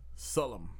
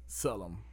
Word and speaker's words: Selorm: The artist's name is Sullum. It's spelled S-E-L-O-R-M. Sullum.